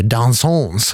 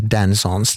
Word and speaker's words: Dansons: Därför ska jag uttala det Dansons och inte Dansons, den här låttiteln.